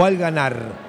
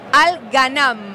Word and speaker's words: Al Ghanam: Perdóname, ¿es Al Ghanam o Al Ghanar? – No, Al Ghanam. – Ah, con N.